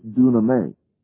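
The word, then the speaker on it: dunamis (δύναμις): That is dunamis. There it is pronounced exactly in this place, dunamis.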